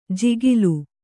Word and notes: ಜಿಗಿಲು: jigilu

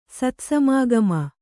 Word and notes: ಸತ್ಸಮಾಗಮ: Kannada

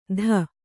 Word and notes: ಧ: dha

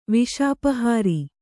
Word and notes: ವಿಷಾಪಹಾರಿ: viṣāpahāri

